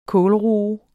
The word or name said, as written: kålroe